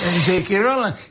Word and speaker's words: J.K. Rowling: J.K. Rowling che ha creato un personaggio pazzesco.